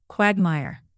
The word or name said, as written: quagmire